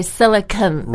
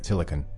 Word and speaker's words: Silicon: 对，我觉得这里大家可以注意第一个词是硅胶嘛，是吧？Silicon, spells like C-O-N, but we don't say Silicon Valley, we say Silicon Valley. – Right, there's no stress on that syllable, so just we go quickly through it, Silicon.